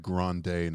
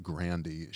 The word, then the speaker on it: Grande: First of all, say Ariana Grande, not Grandy.